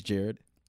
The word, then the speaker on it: Jared: Yeah, it was because my name's Jared. – Jared. – Yeah, just Jared. Yeah. – And it's crazy to see, like, J-A-R-E-D, because when I'm at Starbucks, they spell it every other way but J-A-R-E-D.